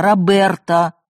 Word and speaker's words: Роберто: У меня папа не Роберт какой-то там, а Роберто с ударением на «е».